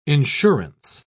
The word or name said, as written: insurance